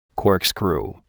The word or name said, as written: corkscrew